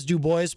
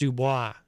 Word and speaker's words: Dubois: Now, he always says Dubois, Pennsylvania, but I really feel like it's pronounced Dubois. D-U-B-O-I-S. That's usually Dubois, not Dubois.